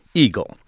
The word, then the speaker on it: EGLE: It will be called the Michigan Department of Environment, Great Lakes, and Energy, and folks are already shortening that to EGLE, pronounced Eagle.